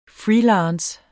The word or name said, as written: freelance